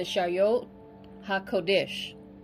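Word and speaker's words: Chayot HaKodesh: Okay, first it starts with the Shayot HaKodesh. Shayot HaKodesh is spelled Shayot, first word C-H-A-Y-O-T, second word Ha, H-A, third word Kodesh, K-O-D-E-S-H.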